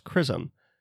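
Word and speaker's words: XRISM: It's X-R-I-S-M, but it's pronounced CRISM.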